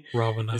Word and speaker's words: Ravana: So Shredder, you're gonna be able to play as uh Ravana, if I'm saying it correctly. – Ravana, it's yeah.